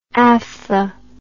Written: aphthous

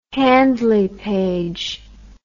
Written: Handley Page

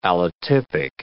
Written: allotypic

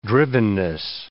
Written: drivenness

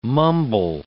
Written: mumble

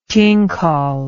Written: tincal

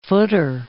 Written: footer

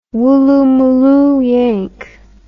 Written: wooloomooloo yank

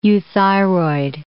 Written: euthyroid

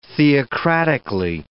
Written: theocratically

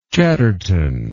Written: chattertonian

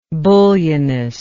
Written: bullionist

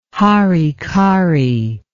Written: hari kari

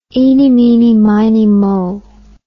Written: eenie meenie minie moe

